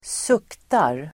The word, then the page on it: suktar: [²s'uk:tar]